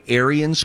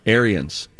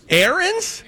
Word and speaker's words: Ariens: I just want to say, Aaron's.